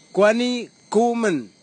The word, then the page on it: kwani kumin: kwamera; Tanna